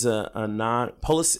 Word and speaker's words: Polis: All right, so Polis is Polis Institute, P-O-L-I-S, like the North Polis cold. It's the way to pronounce it if you see it written.